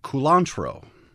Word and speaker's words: culantro: and that is culantro. – Culantro? – Exactly. Not cilantro, but culantro. – How do you spell that? – It's with a U, C-U-L-A-N-T-R-O.